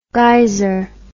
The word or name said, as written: guiser